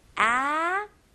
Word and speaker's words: actually: 여기서는 actually. 네, 여기서 ACT 부분에 SH 발음이 나와요. 네, 그래서 act 약간 C 발음에다가 그다음에 surely. actually. actually. 네, 연습 많이 하셔야 됩니다.